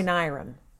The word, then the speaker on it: Knirem: That's got to be a silent K. – It's a silent K. – All right. Roy Knirem, creature supervisor.